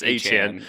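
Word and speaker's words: Achane: It's Achan. – Achan. – Which is kind of crazy because, like, just get that E out of there then, man. What are you doing? – But it's silent. – Yeah, the E is silent.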